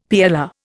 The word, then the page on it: PLR: Indian